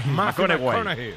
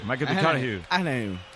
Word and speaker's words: McConaughey: Hay que ir corrigiendo la pronunciación. – McConaughey. McConaughey. Bueno, pues el Matthew.